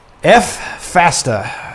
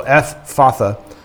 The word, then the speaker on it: Ephphatha: Looking up to heaven, he sighed and said, Ephphatha. I don't know exactly how to say it, but there it is. It's F and then Phatha, right? So Ephphatha, which means be opened.